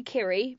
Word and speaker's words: Kiri: Also, I don't really know how to pronounce my, well, I say Kiri, but people say Kiri. Mm. And if it's a Maori name, Rose Matafeo says curry, like that.